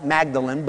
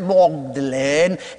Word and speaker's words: Magdalen: And finally, in exasperation, at 2 in the morning on the bridge at Magdalen, it looks like Magdalen, but they pronounce it Magdalen.